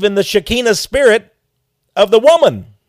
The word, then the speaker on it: Shekinah: The Jews, I think, would pronounce it Shekinah, which also means, by the way, you may divine feminine, you're right.